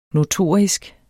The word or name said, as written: notorisk